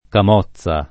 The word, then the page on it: camozza: lingua italiana